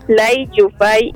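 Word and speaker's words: Lai Yiu-fai: La pequeña hipnosis es que Lai Yai, ay no, perdón, perdone mi pronunciación, o sea, chanclazo. Lai Yufai y Hopo Win, pues ellos viven en Hong Kong, tienen una relación tormentosa, abusiva.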